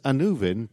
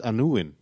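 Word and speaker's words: Annuvin: Well, it has you know, the Land of Death, which was written as Anuvin, but I know it's pronounced Anuin, and they had Aron, the Death Lord or the Lord of Death.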